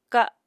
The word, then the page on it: ka7: apatani